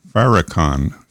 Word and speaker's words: Farrakhan: With a name like Maurice well, moving along, uh, Washington, D.C., uh, the Nation of Islam leader Louis, Louis frakhan I can, I can never say his name right, frakhan Farrakhan, Farrakhan.